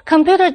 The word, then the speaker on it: computer: attack 这个字有两个音节。或者是 the stress of the word computer is on the second syllable. computer 这个字的重音在第二个音节。或者是 when you say the word debut, accent the last syllable.